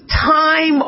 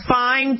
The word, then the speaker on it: thyme: You could use thyme oil. You know, thyme, T-H-Y-M-E. That is pronounced thyme.